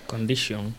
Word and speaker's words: condition: Condition. – Condition, exactamente. Porque esa T del tion se pronuncia... SH, sh, sh, así como si le dijera la palabra sh, cállate.